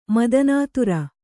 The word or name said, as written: ಮದನಾತುರೆ